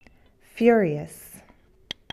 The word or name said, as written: furious